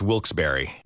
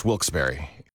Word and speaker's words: Wilkes-Barre: Now, I had heard that it was supposed to be pronounced Wilkes-Barre. Wilkes-Barre Scranton's 97.9X. – The only way to pronounce Wilkes-Barre.